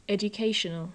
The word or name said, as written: educational